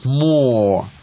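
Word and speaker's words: Moore: Потому что альбом называется, дядя Миша, «We Want More».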